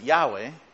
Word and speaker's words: Yahweh: That's we pronounce it Yahweh, but Lord God, Yahweh, Elohim.